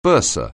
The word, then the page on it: bursar: anglais